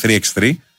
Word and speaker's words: 3x3: FIBA 3-on-3. 3x3. Όχι 3-on-3, συγγνώμη, 3x3. Έτσι λέει, προφέρεται.